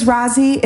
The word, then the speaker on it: Rozzi: My name is Rozzi. It's spelled R-O-Z-Z-I.